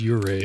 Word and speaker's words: Ouray: I said Ouray the last time. I think it is pronounced Ouray. – Is it? – But it starts with an O.